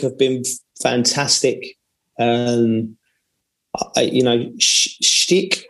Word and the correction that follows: Schick: Patrick Schick.